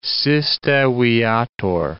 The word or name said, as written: siste viator